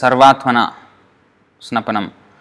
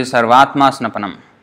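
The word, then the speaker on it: सर्वात्मस्नपनम्: Sometimes I see devotees uh, pronounce this as Sarvatmana Snapanam, but actually Sarvatma Snapanam.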